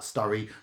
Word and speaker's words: Sturry: Said book never materialised, because in fact he didn't find anything else interesting about Sturry rhyming with slurry, and because a cormorant hatchery was opening in nearby Filage.